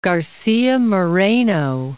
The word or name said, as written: Garcia Moreno